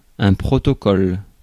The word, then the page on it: protocole: France; [pʁo.to.kɔl]